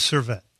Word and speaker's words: Servette: So your first club was Servette? – Yes. – Did I pronounce that correctly? – Yes.